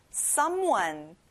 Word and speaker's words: someone: 어떤 사람, 누구, someone. 앞에 some에 힘을 줘보세요.